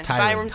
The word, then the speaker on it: Tyrann: Can we agree on a first-name pronunciation? Is anybody? – Tyrann. – Tyrann? – Rhymes with Byron.